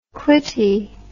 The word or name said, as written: clitty